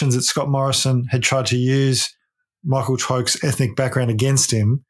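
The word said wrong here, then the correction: Towke: Yeah, so Mike Tauk, I think his surname is pronounced.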